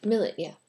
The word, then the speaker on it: millet: Or placing poppy seeds, millet. Is it millet? – Millet, yeah. – It's that's how it's pronounced. Not like Malay.